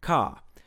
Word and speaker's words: car: So, according to received pronunciation, C-A-R is pronounced something like ka, because the R comes after an A, and that R isn't followed by another vowel. Similarly, P-A-R-K would be pronounced something like pock.